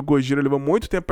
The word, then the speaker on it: Gojira: Em 2001, então, o Godzilla foi legalmente obrigado a mudar o seu nome, e eles acabaram mudando para Gojira, que é a pronúncia de Godzilla em japonês.